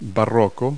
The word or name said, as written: baroque